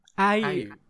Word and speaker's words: IU: Ayu. Sandara Park. – Kasi pag pinapronounce ni Alexa yung Ayu, you lang sinasabi niya.